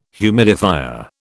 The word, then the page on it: humidifier: British English